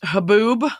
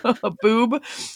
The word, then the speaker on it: Haboob: I believe it's pronounced Haboob. – Yep. – Haboob.